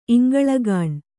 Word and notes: ಇಂಗಳಗಾಣ್: Kannada

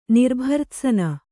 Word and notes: ನಿರ್ಭರ್ತ್ಸನೆ: Kannada

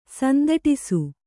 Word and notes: ಸಂದಟಿಸು: Kannada

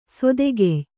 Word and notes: ಸಾದಾಖ್ಯ: sādākhya; kannada

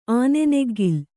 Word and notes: ಆನೆನೆಗ್ಗಿಲ್: āneneggil

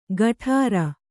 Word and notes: ಗಠಾರ: gaṭhāra

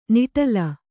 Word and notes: ನಿಟಲ: kannada

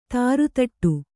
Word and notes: ದರದಟ್ಟೆ: Kannada

